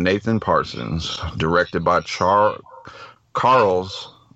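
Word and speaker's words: Carles: In- Carl. Carl's without a without the H.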